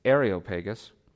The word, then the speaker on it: Areopagus: I emphasize my pronunciation of that because I mispronounced it last week. It's Areopagus.